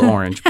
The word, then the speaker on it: Orange: There's a company in France, I believe it's pronounced Orange. It's spelled orange, but it's pronounced Orange because it's French.